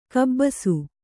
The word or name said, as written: ಕಬ್ಬಸು